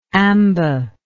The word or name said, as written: amba